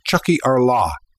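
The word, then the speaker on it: Tiocfaidh ár lá: I won't try to spell it, although I'll email it to anyone who asks, but it's pronounced Chucky Arla, our day will come.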